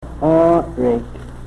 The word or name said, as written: oorik